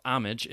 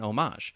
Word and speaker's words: homage: Strictly speaking, that pronunciation ought to be limited to artistic context, where the French word homage, or homage, yeah, the French word homage, he's saying that is the French way to say it, or closer to it, has been reintroduced into English as a term for a work that respectfully emulates that of another artist.